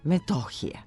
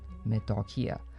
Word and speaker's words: Metohija: A novinarka koja je s njim razgovarala držala se akcenta na drugom slogu – Metohije. – To je greška koja se u govoru ponavlja decenijama, ali ovoga puta nije novinarska. Ispravno akcentovan naziv kotline na jugu naše zemlje, u slivu gornjeg toka Belog Drima i drugog dela naziva naše pokrajine je Metohija, kratko uzlazni na drugom slogu.